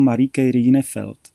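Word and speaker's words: Marieke Rijneveld: Já ti hned odpovím, jenom bych tím, pardon, tak maličko opravil v té výslovnosti. Ona se správně čte jako Marieke Rienewelt.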